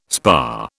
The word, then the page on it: spa: British English